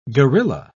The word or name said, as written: guerrilla